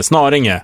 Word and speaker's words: Snarringe: Men herregud, Snaringe. – Snarringe. – Säg ni då. Så är det två R eller? – Ja, det är två R.